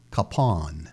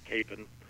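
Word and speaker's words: Kapaun: His name is pronounced a couple ways, and here in Wichita, we usually pronounce it Capon, which is the wrong way. The proper pronunciation is Capon, and from my understanding, the difference in the pronunciation is the difference between it being a Czech pronunciation and a German pronunciation. That the Czech pronunciation of it is Capon, and the German pronunciation would be Capon.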